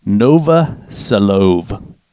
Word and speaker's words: Novoselov: Now, graphene was discovered in 2004 by scientists Andrew Geim and Konstantin Novoselov.